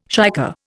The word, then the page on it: shicker: Indian